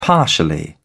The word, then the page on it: partially: British English